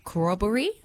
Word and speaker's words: corroboree: 800 critically endangered northern, now, corroboree, am I saying that correctly? – Yep.